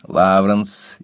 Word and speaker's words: Лавранс: Во всех норвежских именах собственных ударение падает на первый слог. Кристин, Лавранс, Ивар, Еслинг, Сюндбю, Рангфрид и так далее.